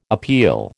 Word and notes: appeal: American English

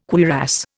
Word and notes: cuirass: Indian

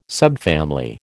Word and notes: subfamily: American English

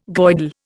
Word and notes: Goidel: Indian

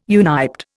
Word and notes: uniped: Indian

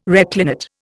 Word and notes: reclinate: Indian

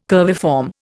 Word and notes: curviform: Indian

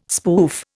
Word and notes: spoof: Indian

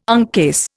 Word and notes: uncase: Indian

